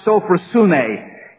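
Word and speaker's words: sophrosune (σωφροσύνη): The first word is the word in the Greek language which is pronounced sophrosune.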